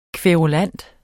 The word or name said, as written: kværulant